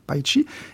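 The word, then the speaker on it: Paicî: La langue Paichi, qui s'écrit en français P-A-I-C-I, accent circonflexe, mais qui se prononce donc Paichi,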